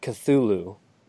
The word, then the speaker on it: Cthulhu: Like, did I mispronounce Chitulu? Chitulu? – So I've always pronounced it Cthulhu. – Cthulhu. Yeah, that might be right, now that I think about it.